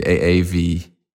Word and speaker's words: Aave: AAVE.